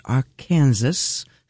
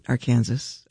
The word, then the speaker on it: Arkansas: The album's called Heart-Shaped Mountain, and that was Arkansas. If you know, that's how they say it in Kansas, right? Arkansas. I kid you not. But Ha Ha Tonka probably says Arkansas.